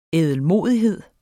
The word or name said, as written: ædelmodighed